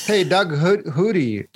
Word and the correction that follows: Flutie: is that how you pronounce it? He made it into the NFL. – Doug Flutie. Yes, he did.